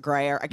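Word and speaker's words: Gruyère: Am I pronouncing Gruyere? – Yes. – I think it's Gruyere.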